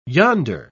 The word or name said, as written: yonder